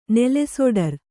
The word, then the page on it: ನೆಲೆಸೊಡರ್: Kannada